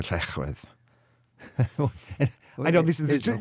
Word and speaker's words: Llechwedd: L is pronounced L, but two Ls are pronounced... – Ech. – Llechwedd.